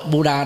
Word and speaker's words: Buddha: Còn đọc theo người Ấn Độ, Buddha đọc là Buddha, vì chữ A ở vị trí là tiếp vị ngữ đó, trở thành là A Câm, không có đọc.